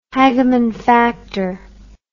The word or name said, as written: Hageman factor